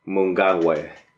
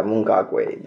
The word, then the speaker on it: Mnangagwa: And I, I said his name wrong. It's Emmerson Mnangagwa. Yeah, Mnangagwa, that's yeah.